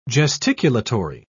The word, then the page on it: gesticulatory: British English